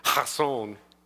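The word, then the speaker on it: hazon (חזון): I guess if you actually pronounce it the way the Hebrews do, it's a hak in there, hazon, like that.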